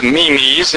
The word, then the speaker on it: meme: M-E-M-E-S, and human susceptibility to religious memes in particular might work to explain how religion might spread like a, quote, mind virus across societies. Like a mind virus. The term meme rhymes with dream.